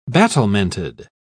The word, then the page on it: battlemented: British English